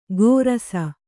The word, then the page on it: ಗೋರಸ: kannada